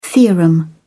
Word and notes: theorem: British English

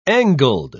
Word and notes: angled: British English

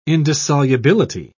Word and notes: indissolubility: British English

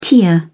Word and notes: tear up: British English

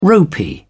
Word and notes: ropy: British English

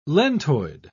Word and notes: lentoid: British English

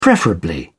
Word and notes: preferably: British English

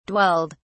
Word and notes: dwelled: British English

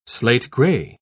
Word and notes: slate-grey: British English

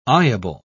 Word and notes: eyeable: British English

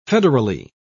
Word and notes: federally: British English

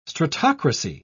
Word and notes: stratocracy: British English

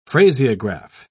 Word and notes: phraseograph: British English

